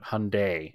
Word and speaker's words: Hyundai: But Hyundai is how it's actually pronounced.